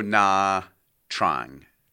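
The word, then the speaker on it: Nha Trang: So I'm going to say it's called Pho Na Trang. Trang. Well, I didn't pronounce any of those words correctly, I don't think. So it's Pho. It's Pho and N-H-A. And then the third word is T-R-A-N-G.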